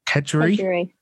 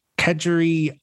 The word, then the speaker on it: kedgeree: But Kedgeree. Is that how you say it? Kedgeree? – Yeah. – So I had a Kedgeree Scotch egg.